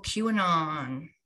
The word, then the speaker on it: QAnon: Oh, QAnon.